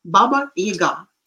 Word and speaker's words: Baba Yaga: Like, for example, this Baba Yaga, we call him Yaga, and somehow it is. Um, the stress is falling on the first syllable, which is uh, wrong. Baba Yaga.